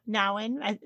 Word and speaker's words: Nouwen: Henri Nouwen, I don't know how Americans say it. You never say his name the same way as me, but... – I think we say... Nouwen. – There you go.